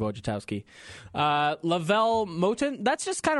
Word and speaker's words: Wojciechowski: Steve Wojciechowski. – It's like, where's your house key, but put Woj on front, okay? – That's great.